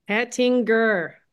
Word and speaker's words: Hettinger: Hettinger, hard G.